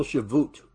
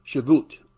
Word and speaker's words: Shavuot: They came to celebrate this annual Shavuot, is how it's said in Hebrew. Shavuot, or the Feast of Harvest, or the Pentecost.